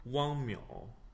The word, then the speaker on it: Wang Miao: you tell me how it's actually pronounced. – Wang Miao. – Okay, I've just been calling him Wang Miao.